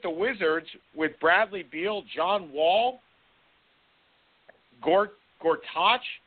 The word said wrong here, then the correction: Gortat: I can never pronounce his name either. – Gortat. – I mean, Gortat. Thank you, Gortat.